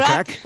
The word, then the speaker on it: craic: Con lo cual ahí podremos, Sharon, decirte a ti y al resto de componentes de The Kors, what's the craic? Creo que lo he dicho bien. – What's the crack? – What's the crack? Es como el how are you, pero en irlandés, ¿vale? – What's the crack?